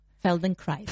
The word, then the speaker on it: Feldenkrais: And I am pronouncing that correctly? – Almost. – Always? Yes. Good. Almost. – It's not easy to pronounce. It's a German word. – Aha. – Feldenkrais. – Feldenkrais. – That's must good. Remember my German teaching, Feldenkrais.